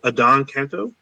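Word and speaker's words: Adan Canto: So I believe his name is pronounced Adon Canto.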